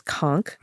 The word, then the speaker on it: conch: looks like conch written out, but the pronunciation is conk, mm-hmm.